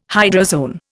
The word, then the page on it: hydrozoan: Indian